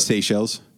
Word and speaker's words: Seychelles: Is that the proper pronunciation? – Seychelles. – Yeah, because S E Y.